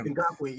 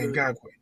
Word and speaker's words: Ngakoue: Ngakoue, yeah. – Yeah, Ngakoue.